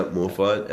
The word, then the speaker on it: Morphite: I'm pretty sure it's pronounced. – Yeah, Morphite. M-O-R... – P-H-I-T-E. Yep.